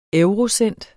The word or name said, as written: eurocent